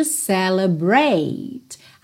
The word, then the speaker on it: celebrate: Now, this is a song to celebrate. Atenção que a gente não pronuncia esse é final aí, ok? Então, celebrate Pode ser até que você nem ouça o T.